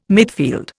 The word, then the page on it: midfield: Indian